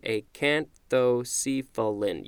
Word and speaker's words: acanthocephalan: Acanthocephalan. A-C-A-N-T-H-O-C-E-P-H-A. Oh, that's an L. L-A-N. Acanthocephalan. And I'm just going to check the pronunciation. Acanthocephalan. Yep.